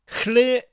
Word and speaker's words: Lle: Chle is spelt L-L-E, and that double L sound is often hard for people to say when they first start learning Welsh. Well, I'm going to show you now how to say it nice and easily. To say it, put your tongue on the roof of your mouth just above your teeth and say L like the L in lid, L, while blowing air.